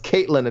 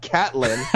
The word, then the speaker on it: Katlyn: I want to read this because he's like, Caitlin, please tell Jose Youngs it's pronounced Caitlin and not Catlin.